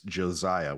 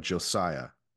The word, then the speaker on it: Josiah: It's Josiah with a soft S instead of Josiah, right? – I think that's everybody.